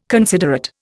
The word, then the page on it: considerate: Indian